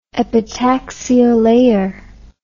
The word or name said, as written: epitaxial layer